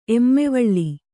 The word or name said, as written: ಎಮ್ಮೆವಳ್ಳಿ